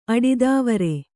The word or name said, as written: ಅಡಿದಾವರೆ